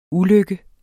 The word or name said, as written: ulykke